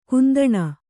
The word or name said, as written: ಕುಂದಣಿ